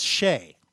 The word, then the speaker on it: Skjei: He was interviewing a player and a Ranger, and he was trying to figure out how to pronounce the guy's name, which was S-K-J-E-I, and he just gave up. That was pronounced Shea.